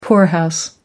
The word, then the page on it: poorhouse: American English